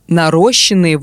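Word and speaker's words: наращённый: Да, Нарощенные волосы и ногти – это ошибка. В этом слове ударный суффикс «ён».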